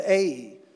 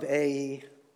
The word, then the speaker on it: Ai: I'm working really hard this morning to pronounce it correctly because all my life I've been taught it was Ai, and you probably have pronounced it that way as well. I was reminded that in Hebrew it doesn't sound like that. It's Ai, not Ai. So if you think I'm pronouncing it wrong, go ahead and check me on that. But it's Ai.